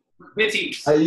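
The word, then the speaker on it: McVitie's: And did I say that wrong? How do you say it? – McVitties. You just did like you did the Pavel pronunciation of the V.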